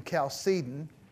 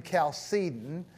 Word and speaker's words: Chalcedon: The fourth council met in Chalcedon, Chalcedon, in 451. And this is the final formulation, Chalcedon, Chalcedon, Chalcedon, Chalcedon, yeah? Or some people pronounce it Chalcedon, but Chalcedon is more correct, corrector.